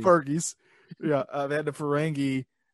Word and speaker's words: Ferengi: Like, because Worf was part of Next Generation, but they had the, who were the Fergie? Am I saying that right? Who were they? What were they called? – Ferengi. – Ferengi, thank you.